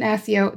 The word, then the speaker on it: Accio: Accio diadem. – Okay, so that's how you say it. So I don't know where I got this from, but I say accio. And I know it's not right because okay.